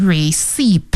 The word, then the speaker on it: receipt: 再来一个就是 receipt、这个 receipt 是收据的意思，那这里有一个拼写 P 是不发音的， R-E-C-E-I-P-T、 receipt， P for Peter 是不发音的，不要念成 receipt，那是错的、正确的是 receipt。